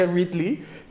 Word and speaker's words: Ridley: Ridley, según yo es Ridley, se pronuncia Ridley y es el malo de Metroid Prime y también de los Metroid originales.